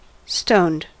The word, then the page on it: stoned: American English